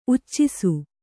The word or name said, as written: ಉಚ್ಚಿಸು